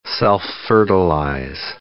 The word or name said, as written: self-fertilize